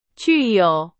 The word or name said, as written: 具有